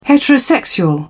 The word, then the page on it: heterosexual: British English